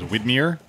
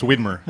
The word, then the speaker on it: Widmer: So Jeff Allworth's book, The Widmer Way. Is it Widmer or Widmer? – In Portland, everybody says Widmer, and they're wrong. It's Widmer. Wait, it's weird. I mean, it's W-I-D-M-E-R.